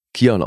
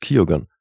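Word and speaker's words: Keoghan: Jedenfalls meinte der dann auch so, das würde eigentlich in Irland würde es hier äh, jeder ähm, Kion aussprechen. Aber weil es halt sein Eigenname ist und er das so ausspricht, es ist mhm. halt Kyogan.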